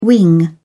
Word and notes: wing: British English

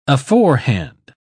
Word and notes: aforehand: English; British English

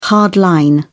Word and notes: hard line: British English

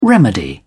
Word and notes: remedy: British English

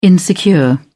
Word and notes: insecure: British English

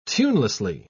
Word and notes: tunelessly: British English

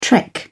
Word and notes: trek: British English

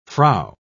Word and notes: frow: British English

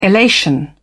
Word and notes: elation: British English

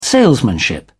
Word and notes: salesmanship: British English

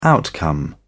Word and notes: outcome: British English

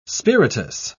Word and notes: spiritous: British English

